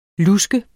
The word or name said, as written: lusk